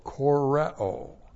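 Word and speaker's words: koreo (χωρέω): This is the aorist active imperative of the verb koreo, plus the accusative plural of the personal pronoun ego. All right, koreo. C-H, long O-R-E, long O, koreo.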